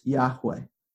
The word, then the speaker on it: Yahweh: And that name, "I am who I am," has four consonants in Hebrew, YHWH, which we pronounce Yahweh.